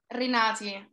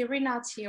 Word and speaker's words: Rinati: Uh, but today, we'd like to thank uh, our special guest for joining us today, Miss Laura or how would you actually pronounce your last name? Is it? – Um, it's Rinati, but uh, that's very hard to pronounce. So you can just say Renati or whatever, whatever.